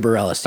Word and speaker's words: Bareilles: Let me also say that Sarah, I forget how you pronounce her last name, Bareilles. Is that right? – Sarah Bareilles, yes.